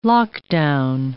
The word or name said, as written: lockdown